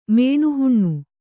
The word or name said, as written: ಮೇಣುಹುಣ್ಣು